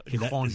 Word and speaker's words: Gijón: Gijón. Gijón. Lo pronuncian la J de otra manera.